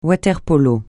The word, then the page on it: water-polo: francais